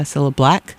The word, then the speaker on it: Cilla Black: I mispronounced her name, Cilla Black. She did Alfie, and that came from a 35th anniversary collection by Cilla Black.